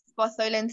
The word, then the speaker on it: Bartholin: I keep saying it wrong. I think it's Bartholinian cyst or whatever. – Bartholin.